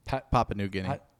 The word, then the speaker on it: Papua New Guinea: Papua New Guinea.